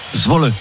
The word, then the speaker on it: Zwolle: Comment tu prononces le club néerlandais? Zwol. – Zwolle. – Zwolle. Zwolle, on prononce le Très bien.